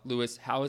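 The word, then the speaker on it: Howes: Lewis Howe, am I saying that right? – Yeah, okay. H-O-W.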